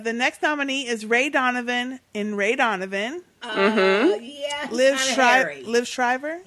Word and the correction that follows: Liev: Actually, he said his name is pronounced Liev.